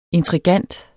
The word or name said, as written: intrigant